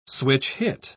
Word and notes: switch-hit: British English

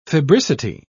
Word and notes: febricity: British English

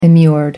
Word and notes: immured: American English

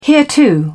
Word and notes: hereto: British English

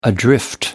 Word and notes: adrift: British English

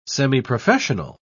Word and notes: semiprofessional: British English

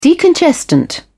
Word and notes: decongestant: British English

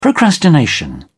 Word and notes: procrastination: British English

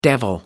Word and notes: the devil: American English

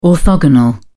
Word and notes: orthogonal: British English

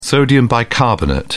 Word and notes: sodium bicarbonate: British English

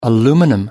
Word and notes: aluminum: British English

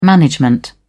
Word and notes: management: British English

